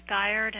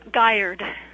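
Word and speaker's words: Giard: Yes, my name is Kate Gyard, and I'm the chairman of the Regulatory Commission of Alaska. – I am very sorry. Would you pronounce your name again? – Gyard. It rhymes with tired, which I am because of daylight savings.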